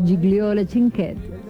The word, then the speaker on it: Gigliola Cinquetti: Sí, Gigliola o Gigliola. Yo la conozco como Gigliola. Sí. Se escribe Gigliola. Pero es Gigliola Cinquetti.